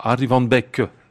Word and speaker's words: Arie van Beek: Nous étions ce soir avec euh, Violaine Cochard, Harry Van Beck, que j'ai bien prononcé. Hein. – Très, euh, bien.